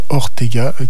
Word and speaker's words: Ortega: S-E-L-A-H, ou Fernando Ortega, Ortega, comme cela se prononce, O-R-T-E-G-A.